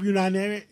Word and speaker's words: Unanue: And, and I understand a million pounds worth of food for uh, Poland and the Ukrainians Tell us, uh, Bob Unanue. I say it right. Yes.